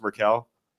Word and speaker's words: Merkel: It's actually pronounced Merkel.